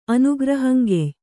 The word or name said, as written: ಅನುಗ್ರಹಂಗೆಯ್